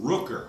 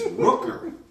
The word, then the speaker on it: Rooker: Rucker, you know? – Right. Rooker. You guys always mispronounce his damn name. It's Rooker. R-O-O-K-E-R. – I love it. – It's not Rucker.